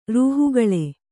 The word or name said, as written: ರೂಹುಗಳೆ